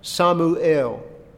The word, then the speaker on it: Samuel: Now in Hebrew, that's pronounced Samuel. Samuel. El being the name for God.